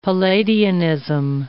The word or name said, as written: Palladianism